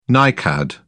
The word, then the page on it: nicad: British English